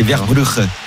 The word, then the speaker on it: Verbruggen: Alors je vais pas te le dire avec la prononciation néerlandaise sinon je vais te complexer, mais comme tu dis Verbruggen, Verbruggen, il y a pas de gueux au Pays-Bas, c'est Verbruggen.